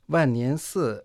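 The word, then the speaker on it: 万年寺: А я слышала, что вес этой медной статуи Будды в храме Ваньяньцы достигает 62 тонн, а ее высота 7 метров 30 сантиметров. – Ваньянсы в переводе означает «храм тысячелетий». Ван означает «десять тысяч», произносится в четвертом тоне.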